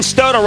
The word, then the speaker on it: Stoto: And, you know, I was talking about this with Ben Stoto, rhymes with photo.